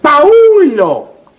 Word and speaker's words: Paulo: Palo Pues En primer lugar, no es palo, es paulo. No, pero la, la U no se pronuncia. Aquí sí se pronuncia. ¿Por qué? – pues porque sí, nada más. Y en segundo lugar, aquí no dice vi, dice sexto.